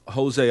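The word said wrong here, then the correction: Jozy: It's not Jose. It's pronounced Josie. Seriously.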